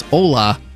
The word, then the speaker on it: hola: No, it's hola. – Oh, it is hola. – Did you just say hola? – Because it's O-L-A. Hola. Hola. – It's like hola, but with a silent H.